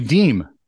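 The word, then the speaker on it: Diem: No, his name is Ass Backwards. It's D-I-E-M. – Yeah. – Should be Deem.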